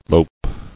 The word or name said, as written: Loping